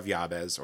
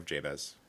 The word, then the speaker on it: Jabez: In 2000, Wilkinson teamed up with Multnomah Publishers to release The Prayer of Yabez, or Prayer of Jabez, however you want to pronounce it, Breaking Through to the Blessed Life.